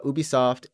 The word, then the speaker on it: Ubisoft: We'll say Ubisoft. – You know, in Japan, it's UBI Soft for some reason. Like, really? That's how they pronounce it.